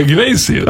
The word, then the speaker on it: Iglesias: Iglesias.